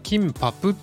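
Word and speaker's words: キ ン パ プ (김밥): これはですねあの発音的にはですねキンパプっていう、ね、最後にプがつくんですけどもね、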